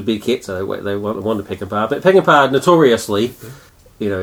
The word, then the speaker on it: Peckinpah: So my first choice by the studio was Peckinpah. Is that how you pronounce his name? – Yep. – Sam Peckinpah.